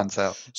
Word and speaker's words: Ancel: Rayman creator Michel Ancel, is that how we pronounce it? – Yeah. Ancel.